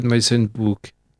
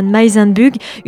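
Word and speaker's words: Meysenbug: Je crois que la prononciation se fait à l'allemande, hein. Malvida von Meisenbuch, une européenne du 19e siècle, et publié donc aux éditions Bartilla.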